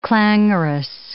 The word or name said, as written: clangorous